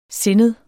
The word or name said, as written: sindet